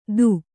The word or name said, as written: ಥೂ